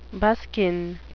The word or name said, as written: buskin